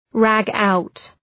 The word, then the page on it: ragout: ræ’gu: